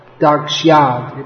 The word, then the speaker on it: Dakshyad: There's a y after the sh, which, don't leave it out. Dakshyad. It's slightly pronounced.